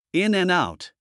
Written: in and out

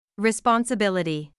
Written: responsibility

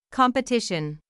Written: competition

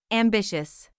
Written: ambitious

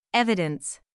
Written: evidence

